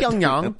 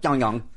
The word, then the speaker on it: Pyongyang: Pyongyang. – Pyongyang.